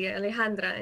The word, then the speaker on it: Alejandra: People, you know, my Miami teachers could pronounce my name, you know, correctly, Alejandra and not Alejandro. And at the O at the end, and then I'm a boy, or just like Alexandra.